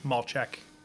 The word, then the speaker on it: Malchek: You'd probably first ask, if you're just reading my last name, how to pronounce that. Malchek. A lot of consonants in there. It's Polish. But 36 years old, and I'm from the East Coast, Connecticut.